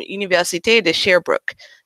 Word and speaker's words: Université de Sherbrooke: He holds a bachelor's degree in physics and master's in education from Université de Sherbrooke.